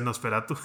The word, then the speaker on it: NOS4A2: que se llama NOS4A2, que se pronuncia Nosferatu,